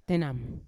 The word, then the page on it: tenam: Yawa